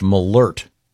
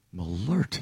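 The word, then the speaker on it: Malört: And this says it's pronounced Malort. – Malort. – There's a, an umlaut, the two dots over the O? This has a pronouncer saying Malort.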